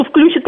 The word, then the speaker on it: включит: Ну, во-первых, так сказать, просто я, например, даже вот на даче, я очень страдаю от того, что кто-то включит радио рядом. Да, включит. Я страдаю от неправильных ударений. Как филолог страдаю от неправильных ударений. На ключ никогда не падает ударение.